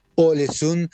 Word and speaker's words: Ålesund: Desde Ålesund, se escribe Ålesund, pero en realidad se pronuncia Ålesund, les mando un abrazo enorme, un viva la radio y un hasta el próximo miércoles desde Escandinavia.